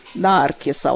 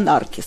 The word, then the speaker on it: Наркіс: Наркіса, от чомусь сковорода Наркіс ставив наголос. Не Наркіс, а це я теж від Леоніда Володимировича, який з рукописами працював, знаю.